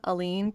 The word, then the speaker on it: Aline: That comes from people have always had trouble pronouncing my name, Aline. People like to say Aileen, Eileen.